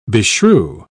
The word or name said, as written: beshrew